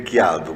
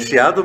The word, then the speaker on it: Chiado: Aliás, a, a, editora, e agora eu fiquei com medo de pronunciar, porque se fosse um vinho, como que antes seria Quiado, mas é Chiado, Chiado. é Chiado mesmo.